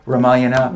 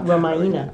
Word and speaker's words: Ramayana: So you think that a modern day, you're pronouncing it Ramayana. – Ramayana.